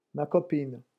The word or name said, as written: ma copine